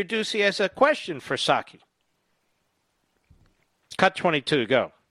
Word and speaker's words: Psaki: So Peter Ducey to Jen Psaki, it's spelled Psaki, but the P is silent.